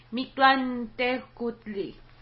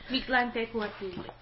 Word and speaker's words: Mictlantecuhtli: I thought it was a little nod to, And the god of thunder, his name is Mictlantecuhtli. – Thank you. That was way better than mine. Yes. – Mictlantecuhtli. – Yes. With the TL pronunciation, I thought that was really cool.